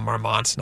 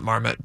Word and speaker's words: Marmont: I think it's called Marmont, not Marmot, but she was at the Chateau Marmont.